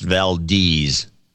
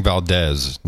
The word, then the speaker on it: Valdez: So, by the way, I found a webpage that from Valdez, Alaska, and it's pronounced Valdez. – Yeah, I don't know why she's saying Valdez.